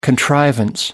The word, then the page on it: contrivance: American English